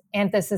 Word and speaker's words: Anthes: Anthus is spelled A-N-T-H-E-S.